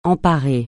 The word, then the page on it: emparer: francais